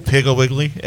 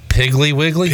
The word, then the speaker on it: Piggly Wiggly: Pickle Wiggly as a brand. – Piggly Wiggly.